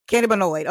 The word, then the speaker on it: cannabinoid: Cannabinoid. – Am I pronouncing it right? – Cannabinoid.